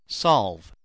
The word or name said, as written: solve